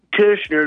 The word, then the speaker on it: Koechner: Well, you should say Koechner. It'd be the German way.